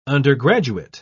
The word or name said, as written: undergraduate